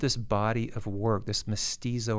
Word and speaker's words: mestizo: One of the chapters of the book addresses what you call mestizo or mestiza mysticism.